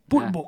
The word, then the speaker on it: Poulbots: C'est Poulpo, ça se dit? – Ça s'écrit, ouais, Poulbo. – P-O-U-L-P-O? – P-O-U-L-B-O-T-S. Poulbo. Ah, parce que sinon, je ne trouve pas. – Tu chercheras. – Poulbo, ah, d'accord, comme beau, d'accord.